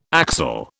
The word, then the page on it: axel: British English